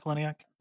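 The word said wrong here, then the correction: Palahniuk: Polonic, I think. – Polonic.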